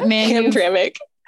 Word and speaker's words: Hamtramck: Yeah, Hamtramck.